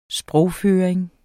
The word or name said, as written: sprogføring